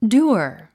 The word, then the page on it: dour: dúər; ダゥア